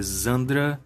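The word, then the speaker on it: Xandra: Eu vou chamar de Chandra, não sei como é que eles vão falar na dublagem. a pronúncia em inglês dela é Zandra, mas de qualquer forma soa muito como Sandra, né?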